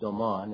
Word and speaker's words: aman: It's pronounced Oman. It's spelled A-M-A-N.